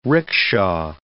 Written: ricksha

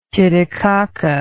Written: titicaca

